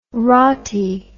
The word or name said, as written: wroughty